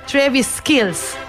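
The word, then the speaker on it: Travis Kelce: Taylor Swift in Travis, kako mi je rekla že Sara, da se izgovori? Travis Kills.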